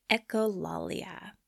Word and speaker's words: echolalia: Echolalia.